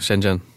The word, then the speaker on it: Shenzhen: Ni etablerar huvudkontor i Shenzhen i Kina. Mm-hmm. Uttalar det rätt eller hur? – Ja, Shenzhen. – Shenzhen, okej.